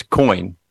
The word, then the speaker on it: Koine: It's Coin. Yeah, just like Coin. Just like coins in your pocket.